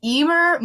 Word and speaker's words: Eimear: It's called Mouthpieces by Emer McBride. That's the Irish name I had to look up. – Oh, yeah. – It's spelled E-I-M-E-A-R.